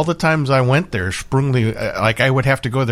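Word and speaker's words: Sprüngli: Sprungli. – Sprungli? – Yeah. – How do you spell that? – S-P-R-U. Well, it's you with umlaut.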